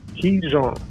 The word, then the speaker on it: qigong: Is it pronounced Kijong? – Kijong.